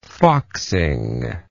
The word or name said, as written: foxing